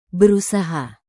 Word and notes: berusaha: indonesian

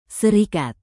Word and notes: serikat: Indonesian

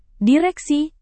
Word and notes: direksi: Indonesian

